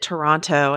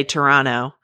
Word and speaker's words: Toronto: I got Canucksplained to like, after I pronounced it Toronto and hit the final T, he's like, don't ever do that if you go there. Never hit the final T in Toronto. You have to say Toronto.